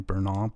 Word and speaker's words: Bernal: It would be B-U-R-N-A-L-L, Bernal. That's a play on my last name, Bernal, B-E-R-N-A-L.